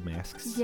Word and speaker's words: masks: They all wear those eye masks. – Yes. – I'm struggling with masks today. – It's okay. – Say it. How do you say it? – Mask. – No, plural. – Masks.